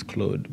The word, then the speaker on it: Claude: The real French pronunciation is Claude, but okay.